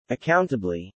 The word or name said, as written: accountably